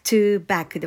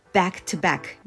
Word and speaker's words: back to back: ると back to back 勢いよくこれで言えるようになりますのでぜひこれは練習してみてくださいなのでここの back to back は back to back ではなくて back to back 2 拍で 12 って感じで back to back っていうような発音になります。